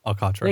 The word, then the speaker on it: Alcantara: Sandy Alcantara? Still know how to pronounce that? No, of course not. – Alcantara. – Thank you.